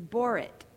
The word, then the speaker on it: borit: Ann Stewart, in her commentary on this little passage, said, I want people to know that the Hebrew word for soap is borit. B-O-R-I-T is how we would spell it. And it sounds very similar to the word for covenant, berit, B-E-R-I-T.